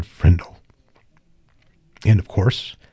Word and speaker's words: Frindle: Nick grabbed the dictionary and leafed to page 541, his heart pounding, and there, between frimmel and fringe, he read Frindle, pronounced Frindle.